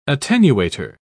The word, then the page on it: attenuator: British English